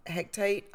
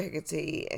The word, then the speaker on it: Hecate: Her name, you can pronounce it Hecate. And some people even called her Hectate, I think.